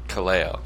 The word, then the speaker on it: Kaleo: Kaleo.